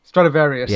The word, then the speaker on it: Stradivarius: Stradivarius.